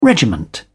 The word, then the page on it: regiment: British English